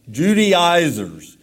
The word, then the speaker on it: Judaizers: It's spelled J-U-D-A-I-Z-E-R. Judaizers.